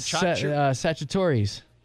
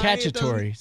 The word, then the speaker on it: cacciatore: Sagittarius. – No, it's not Sagittarius. – Yes, it is. Sagittarius. Tony Sagittarius. – Ends in T-O-R-I. – Cacciatore.